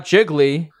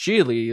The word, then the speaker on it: Gigli: Yeah, it's like, oh, it's not Jiggly. It's it's pronounced Gigli. It rhymes with.